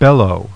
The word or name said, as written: BELLOW